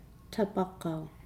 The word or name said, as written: tabagau